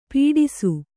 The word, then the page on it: ಪೀಡಿಸು: Kannada